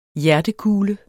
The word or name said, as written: hjertekule